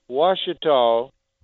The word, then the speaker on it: Ouachita: We have Ouachita, and it's spelled real funny, Q-U-A-C-H-I-T-A.